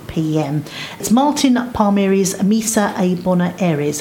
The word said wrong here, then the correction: Buenos Aires: Have I said it correctly? – Buenos Aires. Misa Buenos Aires.